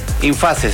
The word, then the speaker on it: Infase: Infase, así, hay que pronunciarlo como se escribe.